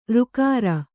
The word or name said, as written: ಱಕಾರ